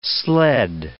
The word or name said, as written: sled